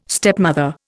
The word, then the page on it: stepmother: Indian